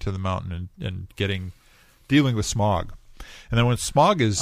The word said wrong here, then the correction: Smaug: Let's pronounce it differently. Smaug. – Smaug. – So that it's not smog. – Smaug. – S-M-O-G.